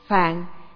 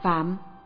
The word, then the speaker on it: phạn: Phạn, chữ Hán là phạn, nhưng thường đọc là phạm.